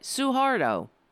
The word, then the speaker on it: Suharto: I said it right. Suhardo.